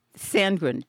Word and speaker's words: Sandgren: Tenny Samgood? – Sandgren. T-E-N-N-Y-S.